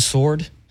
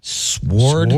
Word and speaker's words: sword: One of us pronounces the W in sword. Sword? – Sword? No.